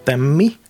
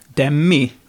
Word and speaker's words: Demme: Eh, Demi? Demi, är det så det uttalas? Ja, jag tror det.